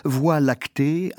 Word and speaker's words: voie lactée: Ralentissez l'écoute, voix lactée, invention de Messier pour son catalogue, ces trois syllabes silencieuses, sans consonne sonore, glissant sur le V, s'accordent au silence de la nuit. Le E muet redoublé, qui les ralentit, ouvre à l'idée de profondeur infinie.